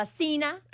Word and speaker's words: esquina: La esquina. – Esquina. – Esquina. – That's right. Good. Great pronunciation.